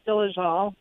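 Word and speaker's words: Dolezal: Pretty close, Dolezel, but fine. – Dolezel.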